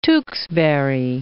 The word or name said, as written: Tewksbury